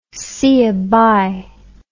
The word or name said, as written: see ya bye